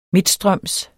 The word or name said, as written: midtstrøms